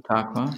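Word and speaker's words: Ta Khoa: My Vietnamese friends tell me Tha Qua. Is that the correct pronunciation? – That's right, Tha Qua, yeah.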